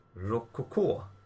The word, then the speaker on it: Rococo: Min tredje plats är ett spel som jag faktiskt bara spelat en gång, men det heter Rococo. – Ja. – Var det rätt uttalat? – Det var rätt uttalat.